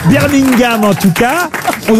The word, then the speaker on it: Birmingham: Voilà, on ne prononce pas le G, Birmingham. – Birmingham.